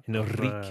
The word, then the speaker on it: Enriquez: Now, did I pronounce Enriquez correctly? – I think you need to roll that. – Enriquez. A little bit more. – Oh, yeah. There you go. You got it.